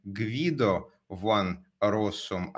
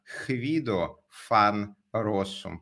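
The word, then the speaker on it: Guido van Rossum: что он не Гвидо ван Россум, а Хвидо фан Россум, потому что в нидерландском языке Г читается как Х, а В читается как Ф.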